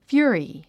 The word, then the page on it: fury: fjú ə ri; ヒユリィ